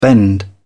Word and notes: bend: British English

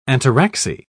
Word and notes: ataraxy: British English

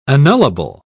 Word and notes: annullable: British English

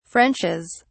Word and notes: frenches: British English

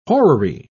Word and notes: horary: British English

